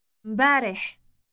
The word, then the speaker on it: امبارح: It starts with a combination of the M and B sounds. Say yesterday again. – مبارح.